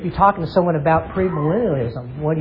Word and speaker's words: premillennialism: Premillennialism.